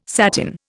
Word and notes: satin: Indian

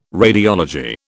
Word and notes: radiology: British English

